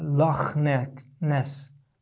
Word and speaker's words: Loch Ness: And in the Scotland, in Scotland there is a word that is pronounced like kha in Arabic, and that is in the word Loch Ness.